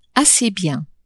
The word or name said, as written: assez bien